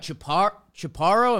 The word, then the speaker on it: Chaparro: Like if you figure out your swing and you're putting in the work, I feel like Chaparro, am I saying that right? He's the name You are.